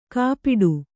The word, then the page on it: ಕಾಪಿಡು: Kannada; kāpiḍu